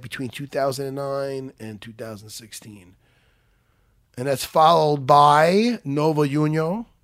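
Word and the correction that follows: União: Did I pronounce that right? – Nova Unyao. – Unyao.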